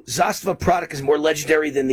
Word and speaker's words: Zastava: All right, sponsor of this segment, Zastava Arms. Am I saying that right? – Correct. – Isn't it Zastava? – You're saying it right. Zastava.